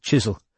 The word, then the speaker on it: Chiswell: "I don't mean Chiswell killing himself." – "It's pronounced Chisel.